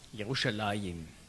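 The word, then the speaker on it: Jerusalem (ירושלים): Zum Namen Jerusalem, auf Hebräisch spricht man das aus, Jerusalem.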